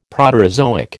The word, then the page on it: Proterozoic: American English